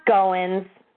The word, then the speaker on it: Goins: Ah, Goins. Like you're Goins. Going in.